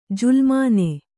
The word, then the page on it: ಜುಲುಮಾನೆ: julumāne